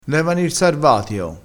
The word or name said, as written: sarvatio